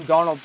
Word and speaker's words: Darnold: You called him Darnold there,